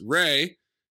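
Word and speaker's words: Rhea: Ray Seahorn, as she explained on her Twitter, it's pronounced Ray, still crushing it.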